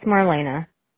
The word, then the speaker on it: Marlena: It's Marlena. – Marlena, and that's spelled M-A-R-L-E-N-A.